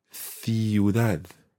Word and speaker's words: ciudad: So in Spain, it's th as in th. So you have sounds like centro. – Centro. – And ciudad.